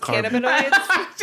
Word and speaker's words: cannabinoids: THC, the drug that the cyborgs use to wipe out memory, was one of a family of drugs called carbonides. – I think it's pronounced cannabinoids.